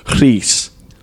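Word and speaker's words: Rhys: Actually, the RH in Welsh is actually pronounced so it's Reese.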